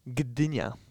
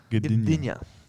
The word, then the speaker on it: Gdynia: Gdynia. – Gdynia? – Gdynia.